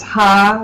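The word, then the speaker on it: Ha (夏): For the record, there is a Cantonese surname that's pronounced Ha, which it's actually Xia in Mandarin.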